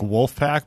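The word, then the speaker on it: Vulfpeck: Like Wolfpack, but pronounced as though you're a German? – Like the German version.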